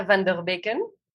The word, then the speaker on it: Vanderbecken: Sofi, ¿cómo se pronuncia tu apellido? – Mi apellido se pronuncia Van der Becken, que se traduciría por de los ríos.